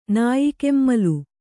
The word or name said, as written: ನಾಯಿಗೆಮ್ಮಲು